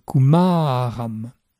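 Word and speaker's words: kumāram: Le mot suivant est kumaram. La syllabe centrale est longue, kumaram.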